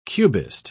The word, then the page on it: cubist: British English